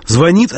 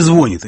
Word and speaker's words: звонит: Звонит, а не звонит.